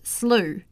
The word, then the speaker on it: slough: I was also schooled on calling that ditch by the side of the road a slough. It's actually pronounced slough.